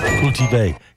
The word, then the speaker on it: cultivé: Un peu plus cultive. Cultivé. There's an accent on the end of it.